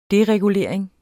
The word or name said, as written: deregulering